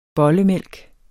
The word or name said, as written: bollemælk